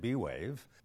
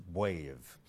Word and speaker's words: Wave: You can pronounce it B-Wave, but you'll be wrong. Let's get back to the name. Why is the trick pronounced wave? The answer is because it is a contraction of the word brainwave. – Donc, vous savez maintenant qu'on ne dit pas B-Wave ou machin, on dit Wave.